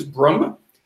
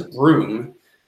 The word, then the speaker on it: Brumme: It's kind of a tongue-in-cheek thing because the last name is Brumm, B-R-U-M-M-E. But everybody mispronounced it as Broom.